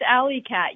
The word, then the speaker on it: Alle-Catt: It's pronounced alley cat.